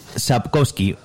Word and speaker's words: Sapkowski: los libros a Andrzej Sapokowski Pero a lo mejor tú lo pronuncias mucho mejor. – Eh, estaba, estaba con eso porque no sé exactamente cómo se pronuncia. Es Andrzej Sapkowski.